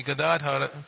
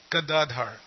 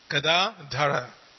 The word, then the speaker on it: Gadadhara: So sometimes people get on each other's case about how to pronounce Gadadhar, but it's there on the screen. It's Gada long A Dara, not Gadadhar. There's no long A in Dara, it's Dara. Gadadhara.